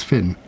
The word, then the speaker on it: Fionn: which is O-I-S with I-N with an accent over it. So it's Oisin. – Oh. – And Finn, of course, it looks like Fionn, F-I-O-N-N, but it's Finn.